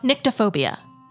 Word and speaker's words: nyctophobia: We're going to talk about nyctophobia. Yes, it is the fear of the dark. – Okay. – It's spelled N-Y-C-T-O, phobia.